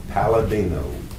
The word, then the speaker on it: Paladino: Raymond, R-A-Y-M-O-N-D. Last name, Palladino, P-A-L-A-D-I-N-O.